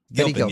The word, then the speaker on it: Gilpin: Like, I think Betty Guplin or Giplin, or how do you pronounce her last Gilpin. – Name? Gilpin, yeah.